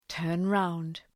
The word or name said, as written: turn round